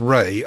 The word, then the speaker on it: Rhea: her first name she pronounces as Ray, R-A-Y, so I learned something there.